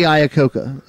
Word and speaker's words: Iacocca: Lee Iacocca is how you pronounce it.